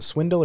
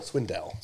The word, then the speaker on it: Swindell: Is it Swindle or Swindell? – Swindell.